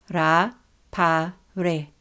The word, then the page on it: Rapare: Ra–pa–re